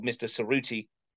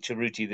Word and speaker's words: Cerruti: And he told me last night that even though his dad is called Mr. Cerruti at his school, he wants now to be pronounced Cheruti, like the Italian way. So we're going with Cheruti this season.